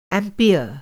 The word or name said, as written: एम्पियर